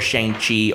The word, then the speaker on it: Shang-Chi: is of course Shang-Chi, as it's pronounced, and The Legend of the Ten Rings is coming out Labor Day weekend.